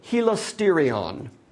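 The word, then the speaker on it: helasterion (ἱλαστήριον): When you read the Greek Septuagint, when they deal with the kapparath, they use the Greek term helasterion. It's a long E.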